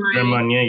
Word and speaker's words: Grand Marnier: How do you say it? Marnier. – Grand Marnier. Yep.